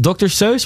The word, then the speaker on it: Seuss: Ja, het is Dr. Seuss, spreek je dat zo uit? – Ja, ja ik denk het wel.